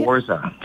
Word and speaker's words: Forza: Forza.